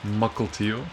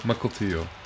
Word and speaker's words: Mukilteo: Mukilteo. – Mukilteo? – Mukilteo.